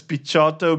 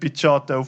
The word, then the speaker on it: Picciotto: So I pronounce this as Picciotto because this is the right Italian way. I'm not sure how Americans actually pronounce it because very often Italian names get slightly butchered. But let's stick with Picciotto for our show.